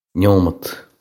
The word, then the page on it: nóiméad: Munster; nyo-mudt; Irish